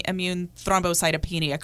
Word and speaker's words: immune thrombocytopenia: Melissa, start by telling us, did I come close to pronouncing immune thrombocytopenia correctly? – Yes, actually you did.